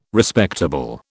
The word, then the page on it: Respectable: British English